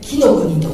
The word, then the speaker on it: 紀伊の国: 紀伊の国と書いて紀の国と読むんです。紀伊というのは今頭に描かないといてください。私が紀の国と言ったらどんな字を、いやどんな字じゃないんですよ、どんな風景を頭に描きますか。紀の国、和歌山は紀の国なんだ。